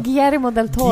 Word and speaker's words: Guillermo del Toro: Germiro. Är det rätt uttalat? Nej, Nej men... du bara hittar på! – Hur uttalas det då? Guillermo del Toro.